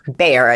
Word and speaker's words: Bhaer: So the chapter's called Friend because it centers around Joe's new friendship that develops with, you pronounce it Bear? – Bear is, yeah. – Yeah, bushy beard. I read this book with a screen reader, like a robot, sexy robot reading it at 100 miles an hour. But it did a pretty good job. I think it said Bear. Even though it's spelled bananasly, B-H, whatever.